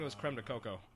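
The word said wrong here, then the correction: crème de cacao: It's pronounced creme de cacao.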